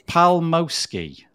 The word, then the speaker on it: Palmowski: I did practice it, and then I've forgotten what I practice. Palmowski. – That's perfect, really. – Is it? – Yes, and when I heard how you pronounce Maciek, I was really impressed, really. I thought that you were practicing like the whole week.